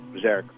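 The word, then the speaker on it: Björk: Actually, I think her name is pronounced Bjork, but that's beside the point.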